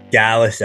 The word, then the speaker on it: Gallus: It's Gallus, right? – Gallus, aye. Got it right in the button.